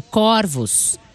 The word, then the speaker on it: corvos: Pesquisei e descobri que, diferentemente do singular, no plural, a palavra corvos é pronunciada com o O aberto. Corvos.